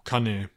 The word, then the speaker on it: Cannae: Ja, Cannae, Cannae.